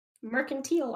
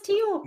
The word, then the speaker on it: mercantile: Tile? I thought it was teal. – No, it's tile.